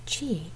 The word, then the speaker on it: qi: Qi, which you pronounce qi, is often translated as vital force, vital energy, or life force.